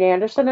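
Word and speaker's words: Andersen: You can find me on Facebook under Maureen Anderson, and I'll caution everybody is that my last name is spelled with an S-E-N, so if you don't find me under S-O-N, there's a reason.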